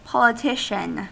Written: politician

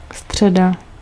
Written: Wednesday

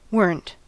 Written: weren't

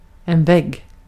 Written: vägg

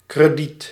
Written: credit

